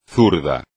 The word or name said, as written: zurdo